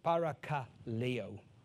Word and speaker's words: parakaleo: The word exhort here is parakleo. Parakaleo. Parakaleo. Kristen? Don't worry about it. She says, you didn't pronounce it right. It's parakaleo.